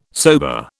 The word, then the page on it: sober: British English